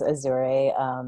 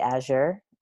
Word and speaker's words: Azure: And I actually think the way Diana pronounces it is very pretty. She says Azure. I say Azure. But of course, when I say that, nobody knows how to Google it or spell it, because that sounds nothing like the way it's actually spelled. So we sort of like the idea that everyone would pronounce it a different way, or Azure also.